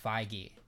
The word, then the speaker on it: Feige: Fun fact, I think I saw an interview yesterday where they said that his last name is pronounced Feige.